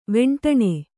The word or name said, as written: ವೆಂಟಣೆ